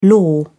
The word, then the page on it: lore: British English